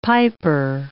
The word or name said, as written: piper